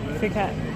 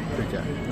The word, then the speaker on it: Phuket: We have to fly to Singapore, and then from Singapore to Phuket, or Phuket. How do you pronounce it? How do you pronounce it? – Phuket. – Phuket.